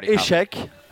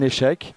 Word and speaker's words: échec: So you've got échec, an échec, like the chess game, échec, or faillite.